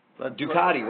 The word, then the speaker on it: Ducati: You have them in one building, and then you introduce the Ducatinisti, you know, which is the all like Ducati rhymes with Bukkake.